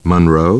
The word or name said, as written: Monroe